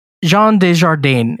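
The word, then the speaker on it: Jean Dujardin: Jean Desjardins. Is that how you say his name, Randy? – Yeah, I think that's close enough.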